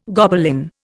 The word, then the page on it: Gobelin: Indian